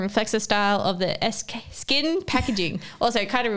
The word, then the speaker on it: SKKN: Is it SKKN or is it Skin? – No, it's pronounced Skin.